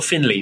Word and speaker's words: Finlay: It's pronounced not Finlay, Finlay. He says it Finlay.